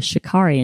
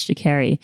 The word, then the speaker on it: Sha'Carri: She did Sha'Carri Richardson, who I learned her name, she pronounces her name Sha'Carri because Kiki Palmer, who was doing the red carpet live stream for the Met Gala, called her Sha'Carri. And she was like, no, it's Sha'Carri.